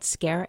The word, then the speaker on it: Skare: My last name is spelled Skare, S-K-A-R-E.